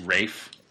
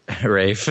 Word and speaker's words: Ralph: it's pronounced Rafe. – Rafe. – Don't Rafe. Disrespect the Rafe.